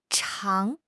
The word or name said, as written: chang2